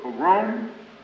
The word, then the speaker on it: pogrom: Concerning the Harlem incident that took place during the summer, when the citizens of Harlem were attacked in a pogrom, how do you pronounce that word? Pogrom? Pogrom, that's what it was.